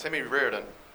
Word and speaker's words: Riordan: Right now, I'm obligated to say my name is Timmy Riordan. That's R-I-O-R-D-A-N.